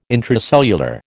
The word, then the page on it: intracellular: American English